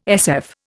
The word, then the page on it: SF: Indian